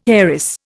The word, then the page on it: heiress: Indian